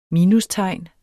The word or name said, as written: minustegn